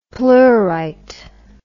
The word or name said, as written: pleurite